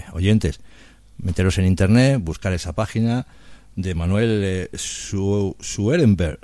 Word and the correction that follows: Emanuel Swedenborg: ¿lo digo bien? – Emanuel Swedenborg, sí.